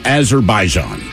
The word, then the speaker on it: Azerbaijan: And today we toast an unidentified 36-year-old man from Azerbaijan.